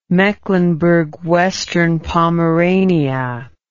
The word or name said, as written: Mecklenburg-Western Pomerania